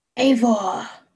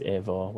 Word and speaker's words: Eivor: Eivor. – It is Eivor.